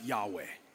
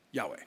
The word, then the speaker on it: Yahweh: You pronounce it Yahweh. Yahweh.